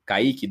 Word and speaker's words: Kaique: Kaique, do jeito que se escreve, com K e com Q no final, é, eu descobri que é de origem indígena e significa ave aquática.